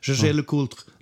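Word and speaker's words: Jaeger-LeCoultre: Så finns det en sådan eh, How to pronounce Gégé le Coultre like a French native speaker och då låter det så här.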